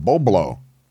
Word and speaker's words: Boblo: Bulblo. You said it wrong. Boblo. – Boblo? – Boblo. The B becomes before the L. Boblo. B-O-B-L-O. Boblo Island. – Boblo. – Boblo. Boblo Island Amusement Park. On Bois Blanc Island. B-O-I-S-B-L-A-N-C.